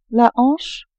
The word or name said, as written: hanche